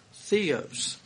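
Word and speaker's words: Theos (θεός): Now, I looked up God's and Strong's Concordance, and it's pronounced Theos.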